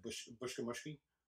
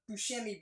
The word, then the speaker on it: Buscemi: Even like Steve Buscemi, never knew how to say his last name. Me and my brother always said Bushkamushki. – Oh, yeah, that's right. – Steve Bushkamushki. – That's right. I always said Buscemi because what was it?